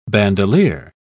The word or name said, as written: bandolier